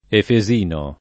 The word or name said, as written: efesio